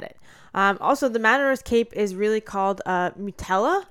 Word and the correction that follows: muleta: Muleta. – I wish you would have fucking told me that before I said this. – That's not getting edited. – Fuck, yeah, I totally mispronounced that. Okay, mutella. Like Nutella? – No, muleta. You did it again.